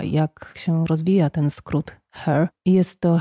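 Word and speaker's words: H.E.R.: I niektórzy na początku y, wymawiali to po prostu y, H-E-R, ale nie. Normalnie się ją czyta her, tak jak się mówi ten zaimek w języku angielskim.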